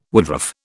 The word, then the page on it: woodruff: British English